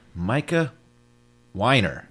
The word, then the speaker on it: Micah Wiener: I have a guest host today, and it is the one and only Micah Wiener.